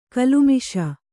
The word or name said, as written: ಗಲಮೀಸೆ